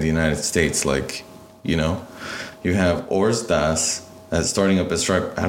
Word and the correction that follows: Ordaz: Nathan Ordaz. – Ordaz, yeah.